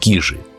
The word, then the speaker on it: Кижи: Сотрудники музея-заповедника произносят с ударением на первом слоге, но уточняют, что оба варианта правильные, потому что есть две версии происхождения слова «кижи».